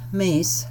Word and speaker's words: Meis: David Eugene Meese, and that's spelled M-E-I-S, died peacefully on January 11th in Chaska, Minnesota.